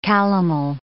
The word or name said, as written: calomel